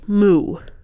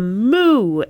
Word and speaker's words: moue: But when you have it in audiobook format and you don't see that it is spelled M-O-U-E, it sounds like somebody is turning with a moo and you just hear moo.